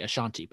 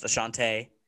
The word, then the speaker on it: Ashante: I keep wanting to say Ashanti, but it's not Ashanti. – I know. It has two E's, but it's pronounced Ashante.